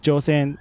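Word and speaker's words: Chōsen: 而且呃朝鲜呃日本殖民政府把呃一九一零年法理上正式并吞朝鲜之后，他又把大韩帝国这个名称改掉，改回原本的朝鲜，而且把朝鲜两个字用日语的发音 Joseon 是拼音是 C，当时候用的拼音是 C H O S E N，就是朝鲜两个字的日语发音。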